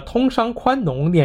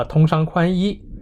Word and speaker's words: 宽农: 这里先需要说明的是，当年愣是把个通商宽农念成了通商宽衣，